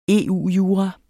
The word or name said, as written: EU-jura